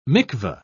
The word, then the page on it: mikvah: British English